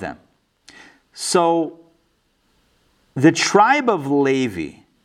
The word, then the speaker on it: Levi: Above the Israelites, you have the tribe of Levi, or Levi. I'm going to say Levi, because that's a Hebrew way of pronouncing it.